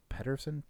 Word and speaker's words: Pedersen: I bet that's a big thing with it, because I'm a Pedersen, P-E-D. It's the Danish way.